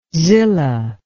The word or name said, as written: Zillah